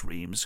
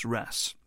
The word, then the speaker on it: Reims: Uh, I don't think it's pronounced Reims because there's a place that's spelt R E I M S, um, and I think it's called pronounced Ras.